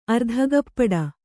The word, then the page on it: ಅರ್ಧಕಪ್ಪಟೆ: Kannada